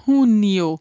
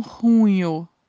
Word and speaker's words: junio: Temos que ter cuidado para não irmos no som do português e falarmos como se fosse com NH, tá? Tem um I aí. Então é junio e não junho, tá? Junio.